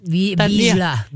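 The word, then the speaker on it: Vizsla: Yeah, but I had to do it phonetically because I was like, the Z, I always say visla or something, visla, and I'm like, no. – Visla. Yeah. Visla. It's Hungarian.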